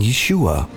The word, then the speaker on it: Yeshua: We understand who we're talking about, and yet we're probably not unfamiliar with the fact that if we pronounce this in Hebrew, we'd be saying Yeshua.